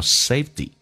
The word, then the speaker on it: safety: My parents believe in the saying, believing, 那么 believe 跟 in 叫 believing, in 跟 the, 我们刚刚有讲过可以说成 in a, believe in a saying, 相信这样的一句话叫做 safety first, 安全第一，安全至上 safety, 那么这个 T 在美式发音要浊化成 D 叫 safety, 我们把这一段慢速的版本来读一遍 ,here we go, My parents prefer to live in a country, the streets there are cleaner, The air is fresher, and the people are nicer and friendlier.